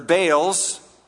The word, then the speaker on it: Baal: They forgot the Lord their God and served the Baals. Right pronunciation is Baal and Asherahs. I told you last week I would explain that. Baal or Baal means Lord.